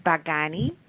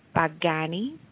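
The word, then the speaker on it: bagani (बैंगनी): The second color is red, which is lal, which is spelled L-A-L-A, but it's pronounced lal. The third color is violet, which is bagani, and that is spelled B as in boy, A-I-N as in Nathan, G as in ground, A-N-I, bagani.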